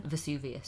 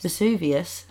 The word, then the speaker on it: Vesuvius: Vesuvius. – Vesuvius. It's thought to have erupted about 42 times since. Ooh. The last eruption was 1944. – Oh, right, okay. Mm-hmm. So we're probably due a Vesuvius.